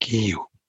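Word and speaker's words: Eeyou: Iyu, which you pronounced right, by the way.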